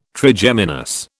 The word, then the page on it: Trigeminus: British English